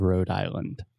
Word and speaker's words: Rhode Island: Okay, can you spell the word road for me? – R H O D E. – Yes. – Which is the same when you say it, the pronunciation is the same as road, like street, road, R O A D.